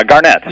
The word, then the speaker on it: Garnett: Arnett, or, Garnett.